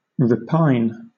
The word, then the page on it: repine: British English